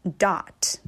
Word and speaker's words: dotted: 接下来呢 dotted 的这个发音呢，这个 o 注意，这个 o 它发音不是也不是啊，它是一个处于这个之间的这么一个发音。 dot 不是 dot 也不是 dot，是 dot。